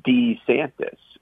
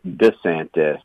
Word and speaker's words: DeSantis: Ron DeSantis, for most of his life, pronounced his family's name DeSantis. It is only in the last several years that he has started saying it with a softer D at the front, DeSantis, and that was a preference of his wife, of the current First Lady of Florida.